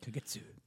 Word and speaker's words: Kagetsu: In 8-woman tag, Sumi Sakai, Jenny Rose, Maya Owatani, and Tennille Dashwood taking on Kelly Klein, Kagetsu. I hope I said that right. – Kagetsu. – Yeah, there we go.